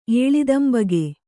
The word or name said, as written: ಏಳಿದಂಬಗೆ